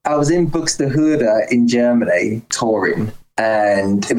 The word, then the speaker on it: Buxtehude: But the song Dietrich Buxtehude. – Buxtehude, yeah, you said it right.